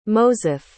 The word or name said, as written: moseph